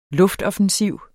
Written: luftoffensiv

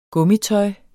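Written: GUMMITØJ

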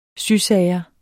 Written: sysager

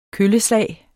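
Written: KØLLESLAG

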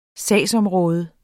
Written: sagsområde

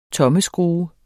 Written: tommelskrue

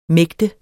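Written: mægte

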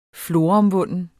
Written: floromvunden

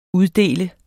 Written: uddele

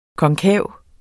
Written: KONKAVE

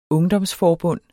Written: ungdomsforbund

